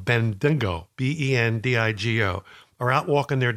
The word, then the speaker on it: Bendigo: B-E-N-D-I-G-O.